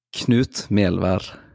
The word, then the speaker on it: Knut Melvær: Knut Melvar.